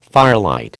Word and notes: firelight: American English

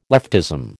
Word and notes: leftism: American English

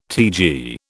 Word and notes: TG: British English

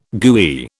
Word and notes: gooey: British English

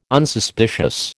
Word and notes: Unsuspicious: American English

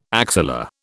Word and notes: axilla: British English

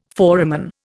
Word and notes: foramen: Indian

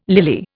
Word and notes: lily: Indian

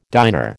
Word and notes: diner: American English

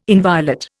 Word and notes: inviolate: Indian

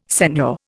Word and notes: seigneur: Indian

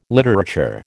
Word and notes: literature: American English

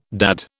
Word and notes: dad: British English

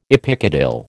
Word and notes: epicotyl: American English

